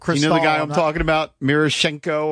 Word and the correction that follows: Miroshnichenko: or whatever. – Miroshnichenko. – Yes. – Who had a little bit of time up here last year. – Forgot a couple syllables.